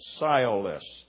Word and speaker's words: sciolists: And that's the way you pronounce that word, sciolists.